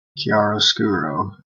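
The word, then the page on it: chiaroscuro: Canadian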